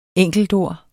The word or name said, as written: enkeltord